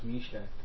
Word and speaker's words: Meshach: So that's pronounced Meshach. – That's usually the way it's pronounced.